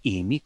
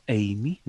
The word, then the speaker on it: Amy: Uh, In potem je recimo ogromno psov, danes nosijo ime Amy, ne, ne Amy, kot bi rekli po slovenskem, ampak lepo Amy, ne, kar je eh, čista angliška izgovorjava.